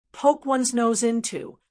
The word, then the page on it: poke ones nose into: American English